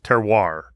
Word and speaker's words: terroir: Terroir, right? Is how it's pronounced? – Yep, yes.